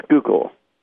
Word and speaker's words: Schuylkill: It's Schneidel, and the county is pronounced Schuylkill.